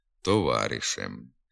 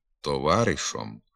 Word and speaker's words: товаришем: Наприклад, «динею», «працею», «грушею», «гаєві», «товаришеві», «гаєм», «товаришем». Вимова тут замість «е» звука «о» – «динею», «працею», «грушою», «гайові», «товаришові», «гайом», «товаришом» відома в багатьох говорах, не відповідає літературній нормі.